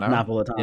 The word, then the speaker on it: Napolitano: Napolitano, yeah.